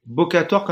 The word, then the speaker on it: Bokator: Comment, tu l'écris B-O Bokator, comme ça se prononce.